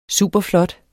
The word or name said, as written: superflot